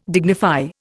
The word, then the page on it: dignify: Indian